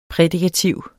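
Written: prædikativ

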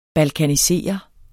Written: balkanisere

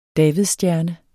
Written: davidsstjerne

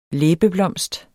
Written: Labiatae

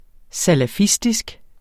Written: salafistisk

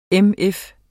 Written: MF

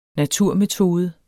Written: naturmetode

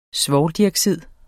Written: svovldioxid

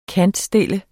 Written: kantstille

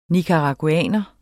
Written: NICARAGUANERE

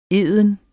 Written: eden

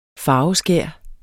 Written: farveskær

